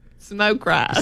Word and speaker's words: Smoke Rise: Smokies. – No. Smoke Rise.